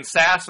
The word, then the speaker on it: Sasse: Ben Sasse, am I pronouncing the last name correctly? – Yes, you are.